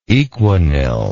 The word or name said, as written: equanil